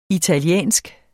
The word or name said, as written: italienske